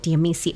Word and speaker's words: Amicis: Amicis. – Oh, the S is silent.